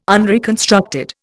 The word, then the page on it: unreconstructed: Indian